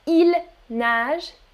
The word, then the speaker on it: ils nagent: But they are pronounced exactly the same. On prononce exactement de la même manière. Il nage and il nage, singulier, pluriel.